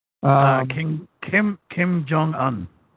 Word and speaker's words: Kim Jong-un: Kim Jong-un.